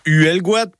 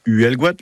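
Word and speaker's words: Huelgoat: On le retrouve dans uelguat, par exemple, la forêt haute. Hein, uelguat, là aussi, je profite pour la prononciation. Uelguat, hein, ne cherchez pas autre chose, c'est comme ça.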